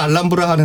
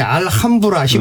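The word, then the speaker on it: Alhambra: 이게 뭐 스페인이나 라틴어권에서는 H를 묵음으로 수리하는 경우가 많으니까. – 맞습니다. – 네. – 그래서 이어서 알람브라 알람브라 하는데 예. – 한국 사람은 알함브라 힘을 네. 주는데 원래 알람브라가 맞죠.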